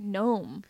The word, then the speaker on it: Noam: I know someone named, like, Gnome. – Gnome Jones? – Get out of here. For real? – Yeah. – Like, with a G? – No, it's spelled Noam.